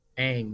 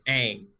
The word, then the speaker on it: Aang: Every time in my head, I say Aang because that movie was like so terrible. And I'm like, why do they keep saying his name like that? That's wrong, that I forgot how to say it the correct way. – Aang, man. It's Aang.